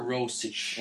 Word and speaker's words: Rosic: So Rosic would be the nice one for everyone easy. – The nice version. – That's the nice easy one? Okay, and what's the real pronunciation? – Since I come from an Eastern European country and, you know, I'm such like an old-timer and everything, you can refer to me as Mr. Rosic.